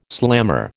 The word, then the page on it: slammer: American English